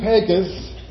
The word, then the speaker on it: Pecos: Well, after we passed through Pecos, and I learned that's how you say it, it's not Pecos, that's what I kept saying. They're like, no, it's Pecos.